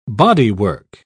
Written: bodywork

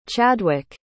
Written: Chadwik

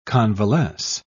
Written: convalesce

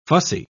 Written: fussy

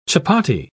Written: chapati

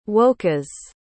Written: wokas

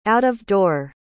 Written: out-of-door

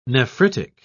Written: nephritic

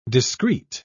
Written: discreet

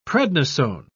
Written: prednisone

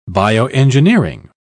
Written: bioengineering

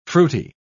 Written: fruity

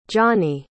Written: gianni

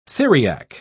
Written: theriac

